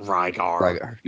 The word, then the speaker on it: Rygar: Rygar.